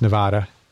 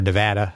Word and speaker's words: Nevada: He's out of Las Vegas, Nevada, or Nevada, I guess, if you're going to pronounce it as the locals do.